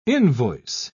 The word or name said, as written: invoice